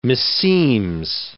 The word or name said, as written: meseems